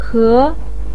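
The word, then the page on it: ke: tone 2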